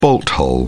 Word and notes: bolt-hole: British English

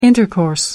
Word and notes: intercourse: American English

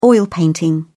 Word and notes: oil painting: British English

